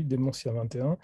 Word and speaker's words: Dementia 21: Et dans la même catégorie manga, toi tu as lu notamment Dementia 21, je ne sais pas si je le prononce bien. – Oui, oui, Dementia 21.